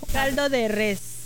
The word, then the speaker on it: caldo de res: So how do you say, ¿cómo se dice caldo de res or caldo de rey? – Caldo de res.